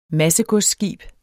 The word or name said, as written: MASSEGODSSKIBE